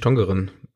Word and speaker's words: Tongeren: Die, es gibt beide Schreibweisen da irgendwie und so ganz haben wir nicht aufgedeckt, wann man welche nutzt, aber ich würde jetzt einfach mal äh, Niederländisch Tongeren aussprechen.